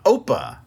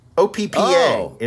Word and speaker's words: Oppa: Yeah, Open Gangnam Style. – Oppa. OPPA is that Korean word. – Oh, Oppa. – Oppa Gangnam Style. Right. That's how you say it.